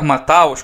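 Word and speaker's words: Matthäus: Lothar Mateus, ou Lothar Mataus, como alguns pronunciam, eu só vi por vídeo e me parecia ser um puta de um jogador.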